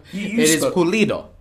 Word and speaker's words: Pulido: How do you say his name? – Pulido. – Pulido. – Pulido. – All right. – Don Carlos Pulido.